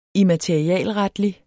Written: immaterialretlig

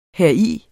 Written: heri

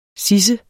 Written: sidse

